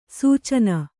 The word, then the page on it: ಸೇಚನೆ: Kannada